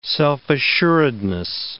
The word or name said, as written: self-assuredness